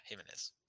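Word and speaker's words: Jiménez: Yeah, Jimenez.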